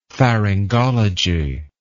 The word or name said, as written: pharyngology